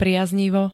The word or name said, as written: priaznivo